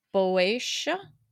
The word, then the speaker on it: Boeotia: So, so you said that Asthma was king of Boeotia.